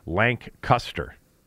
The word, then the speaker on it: Lancaster: Not taken to task, but Faust on Twitter said, you keep mispronouncing Lancaster, PA. And I said, well, spell it phonetically for me. And he spelled it LANCCUSTER, Lancaster. You know Pennsylvania.